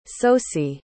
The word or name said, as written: sosie